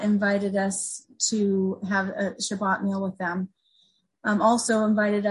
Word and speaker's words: Shabbat: Shabbat is how you say it in Hebrew.